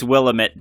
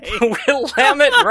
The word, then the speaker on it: Willamette: We'll get that one and the it's Willamette, damn it, shirt. – Yeah, no, Willamette, Willamette. – No, I did it wrong anyway. – Willamette rhymes with damn it.